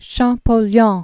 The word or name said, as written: Jean Francois Champollion